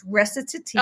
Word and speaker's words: recitative: So, well, first I'll pronounce it. It's recitative.